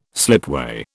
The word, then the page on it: slipway: British English